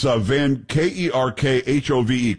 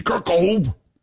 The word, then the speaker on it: Van Kerkhove: It's Van Kerkhove. Kerkhove. I'm pretty sure that's how you pronounce it.